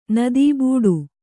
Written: ನದೀಬೂಡು

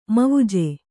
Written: ಮವುಜೆ

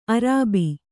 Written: ಅರಬ್ಬಿ

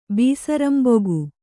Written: ಬೀಸರಂಬೊಗು

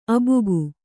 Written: ಆಭೋಗ

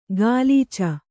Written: ಗಾಲೀಚ

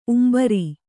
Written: ಉಂಬರಿ

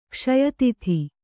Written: ಕ್ಷಯತಿಥಿ

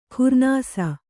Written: ಖುರ್ನಾಸ

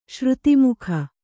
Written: ಶ್ರುತಿಮುಖ

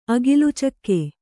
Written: ಅಗಿಲುಚಕ್ಕೆ

